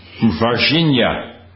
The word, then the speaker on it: Varginha: Varginha. It's like Virginia, but you got to give a zh. It's Virginia with a zh.